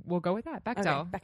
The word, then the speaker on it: Bechdel: I kept hearing it pronounced Bechdel. – Bechdel. – Yeah. – Well, that's how Anita Sarkeesian says that. It.